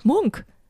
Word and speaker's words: Munch: Aber genau, ich schaue hier zum Beispiel auf, ja, man sagt so schön Coffee Table Books, also große Werk von Edward Munch, oder spricht man eigentlich Munch oder Münch? Munch, ne? – Munk. Ich glaube. – Edward Munk. – Ich glaube, dass der Munk heißt, aus Norwegen.